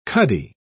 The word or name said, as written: cuddy